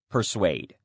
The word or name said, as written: persuade